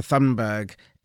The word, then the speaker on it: Thunberg: Well, and I would say to them, first of all, it's pronounced Thunberg. Can't help it. Sorry. Overactive feedback gland. Scandinavian.